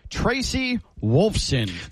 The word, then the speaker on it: Tracy Wolfson: Tracy Wolfson.